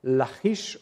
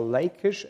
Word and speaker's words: Lachish: It's the site of Lachish, or Lachish, as some would prefer to pronounce it in English, in your Bibles.